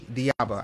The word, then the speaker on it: Dheeab: D-H-E-E-A-B as in boy, A, Diaba.